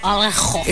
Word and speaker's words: Alejo: Alejo. – It's A-L-E-J-O. How do you say? Alejo.